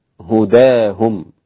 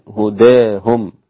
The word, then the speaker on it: هداهم: ليس عليك هداهم، أمال هداهم الإمام حمزة والكسائي وخلف، وقللها الإمام ورش وكذلك أبو عمرو، فيصير النطق بها فتحا هداهم، تقليلا هداهم، بالإمالة المحضة هداهم هكذا.